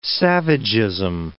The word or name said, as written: savagism